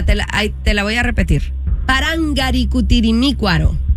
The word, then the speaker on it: Parangaricutirimícuaro: Parangaricutirimícuaro. – Me la mataste ahí. – Parangaricutirimícuaro. – No, le faltó. Oh, una sílaba.